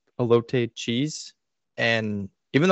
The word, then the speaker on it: elote: So with that in mind, what I said about the cheese, the elote, did I get the pronunciation right this time? – I think Kyle's are.